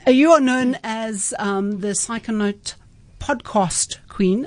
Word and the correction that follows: Psychonaut: I've actually said that wrong. Psychonaut. – Psychonauts, yes.